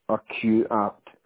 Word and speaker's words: Urquhart: I think, yeah, H-A-R-T. – Urquhart House. – House, yeah. – Urquhart, it's pronounced, it's U-R-Q. – Yeah. H-A-R-T.